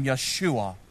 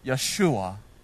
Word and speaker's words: Yeshua: In Hebrew, we would have pronounced his name Yeshua. Yeshua.